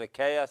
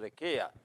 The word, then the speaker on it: Achaia: Some pronounce it Achaia, some pronounce it Achaia.